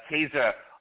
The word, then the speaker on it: Caesar: or in Latin pronounced with a hard C, Caesar.